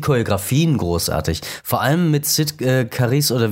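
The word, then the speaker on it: Cyd Charisse: Sid Charisse.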